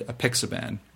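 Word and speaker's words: Apixaban: These are Dabigatran, the trade name is Pradax, Rivaroxiban, trade name is Xarelto, and Abixaban, and the trade name is Eliquis. Did I say that right? – Eloquently.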